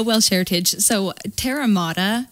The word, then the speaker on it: Terra Mater: So when the name Terra Mater came to me, the pronunciation that I've chosen is Terra Mater, and Terra, T-E-R-R-A. Mater is M-A-T-E-R.